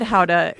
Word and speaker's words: Gouda: I know that gouda is the correct pronunciation, but people, that's a difficult way to communicate with people in Iowa.